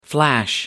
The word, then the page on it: flash back: American English